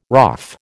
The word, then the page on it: RAAF: American English